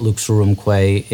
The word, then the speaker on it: Lux Aurumque: Well, first, your pronunciation of it is spectacular. – Oh, good. I got it. – Yeah, you got it perfectly. So your listeners know that Lux Aurumque, that crazy title, it's just Latin, and it means light and gold.